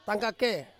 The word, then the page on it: kake: Ansus